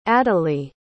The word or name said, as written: adalee